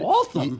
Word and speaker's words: Waltham: All right, this is in Waltham, Mass. For the third... – Waltham? – Yes.